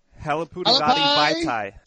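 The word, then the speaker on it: Halapoulivaati Vaitai: Halapudavati Vaitai.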